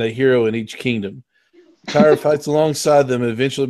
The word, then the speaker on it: Tyor: Uh, the three kingdoms have been overtaken by three evil lords, and only uh, Tyre, T Y O R, is how they pr- pronounce it.